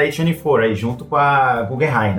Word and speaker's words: Guggenheim: Seria, eu acho que é Google. Guggenheim. Guggenheim, vamos Sim. lá. Sim.